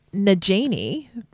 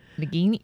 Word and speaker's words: Nagini: Nagini, yeah. – Nagini. – Nagini. – Oh, because in my head I went Nagini in a bottle. That's how you say it. No, Nagini.